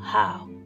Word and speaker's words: Hao: And our close is, we say how. And that's spelled H-A-O, how.